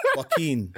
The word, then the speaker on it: Joaquin: So we've got Jaquin. I said it wrong, did I? – Joaquin. – Joaquin Phoenix.